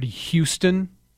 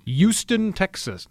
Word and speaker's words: Houston: Somebody throws the silent H on the word Houston. – Yeah. – The newscasters will do that. Houston, Texas. No. – Yeah. – Houston, Texas.